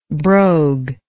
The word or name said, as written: broguery